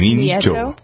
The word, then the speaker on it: Nieto: Here's a reporter's rap on the president of Mexico, Enrique Peña, how do you pronounce his name? His last name? – Nieto. – Nieto? Okay, yeah, so there it is.